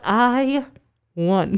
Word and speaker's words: Ione: It's spelled I one. The letter I-O-N-E. Ione.